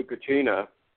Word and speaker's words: Kachina: Kachinka, or whatever they, you know, whatever the Hopis had to say. – Yeah. – I'm not sure Kachina. – Exactly how you pronounce it.